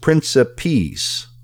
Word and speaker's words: principiis: I had never heard of it before, but obsta principis, that's O-B-S-T-A, first word, second word, principis, P-R-I-N-C-I-P-I-I-S, and it's pronounced principis.